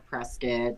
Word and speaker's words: Prescott: So, because if you pronounce the O, you're going to spit it all out. So, that's allegedly mm-hmm. why it's called Prescott, and it's also just easier off the tongue, I guess.